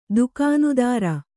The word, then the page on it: ದುಕಾನುದಾರ: kannada